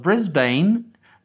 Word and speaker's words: Brisbane: He's getting something set up on his own, and the people of Brisbane, notice how I pronounce that, which is the correct pronunciation,